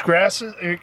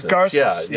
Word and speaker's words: Garces: Rich Grasses. – Garces.